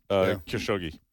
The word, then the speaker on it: Khashoggi: Khashoggi.